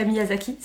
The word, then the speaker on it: Miyazaki: Hidetaka Miyazaki, c'est ça, je le prononce bien.